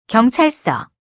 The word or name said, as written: 경찰서